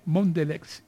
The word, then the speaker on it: Mondelez: Bueno, gerente de Asuntos Públicos, Comunicaciones y Sostenibilidad de Mondelez Internacional. ¿Lo pronuncié bien o no? – ¿Mondelez o Mondelez? Sí, así es. ¿Mondelez o Mondelez? Cualquiera de las dos opciones está correcta.